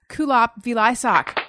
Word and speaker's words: Kulap Vilaysack: My guest today is return guest Kulap Vilaysack.